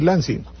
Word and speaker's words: Lansing: Lo que pasa es que el mérito, entre comillas, de Lansing, o Lansing, se escribe L-A, yo creo que debo decir Lansing.